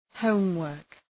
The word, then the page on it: homework: ‘həʋm,wɜ:rk